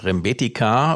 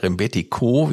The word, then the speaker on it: Rembetiko: Ich lese hier gerade im Netz, dass Rembetika oder Rembetiko, wie es ausgesprochen wird im Griechischen, als griechischer Musikstil insgesamt vor allen Dingen gilt, der eben die Verbindung der Volksmusik Griechenlands und der osmanischen Musiktradition zusammenbringt.